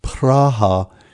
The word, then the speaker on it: Praha: My wife and I will be celebrating our 50th wedding anniversary in Prague, or more accurately pronounced Praha, in the Czech Republic.